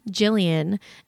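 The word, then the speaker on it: Jilliane: Okay, I understand that my name, Jillian, is spelled kind of differently because I have an E at the end. It's J-I-L-L-I-A-N-E.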